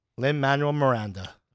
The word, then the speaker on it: Lin-Manuel Miranda: Pretty sure you didn't say that right. – Lin-Manuel Miranda. – Okay.